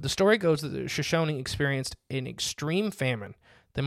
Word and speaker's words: Shoshone: The Shoshone tribe. I always say it wrong. – Yeah. – Because it's spelled almost shoeshine.